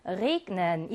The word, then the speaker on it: regnen: 그 다음 비가 내리다 라고 하는 동사의 G도 자음 앞에 G가 나와 있지만 G로 발음해서 Regnen 이라고 해요.